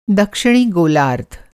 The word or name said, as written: गोलार्द्ध